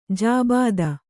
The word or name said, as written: ಜಾಬಿತಾ